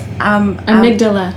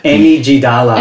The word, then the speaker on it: amygdala: Amygdala. – Amygdala. – Amygdala. – Amygdala. Amygdala. – Amy. – Amygdala. Amy G. Dalla.